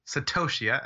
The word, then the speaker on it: Satoshi: Satoshi.